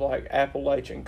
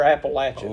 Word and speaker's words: Appalachian: It's interesting because we're on the southern end of Appalachian culture, or Appalachian. – Ooh, I know it was a gaffe. You would get thrown out of West Virginia for saying that. – But pronouncing it that way.